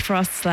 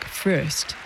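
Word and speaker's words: Fröst: By the way, Frost's last name is spelled with two dots over the O in umlaut, so in his native Swedish, it's pronounced more like Frost. But in English, everyone seems to just agree to pronounce it Frost.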